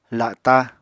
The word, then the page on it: la7ta: Apatani